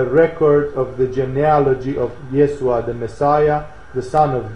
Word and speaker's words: Yeshua: Sometimes they call him Yeshua in this text, simply because it seems that the Aramaic pronunciation would have been close to that to Yeshua.